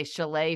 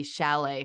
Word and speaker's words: chalet: That I think I say it wrong every time. I say chalet frame, and I think it's supposed to be chalet frame.